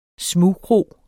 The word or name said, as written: smugkro